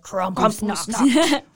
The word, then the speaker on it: Krampusnacht: Saturday night, they're having their Krampusnacht. – Love it. – You have to say it like that, like Krampusnacht.